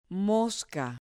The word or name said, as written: mosca